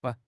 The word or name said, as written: ban1